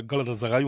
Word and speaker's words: Galatasaray: Und steht im Moment auf Platz 3 hinter Galatasaray und Fenerbahce, aber okay. Vor Besiktas. – Ne, und genau so spricht man die Vereine aus.